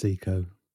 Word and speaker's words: Sico: I've just watched a little video, and it's Seiko.